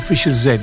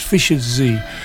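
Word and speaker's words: Fischer-Z: Some people pronounce it Fisher Z. It's Fisher Z.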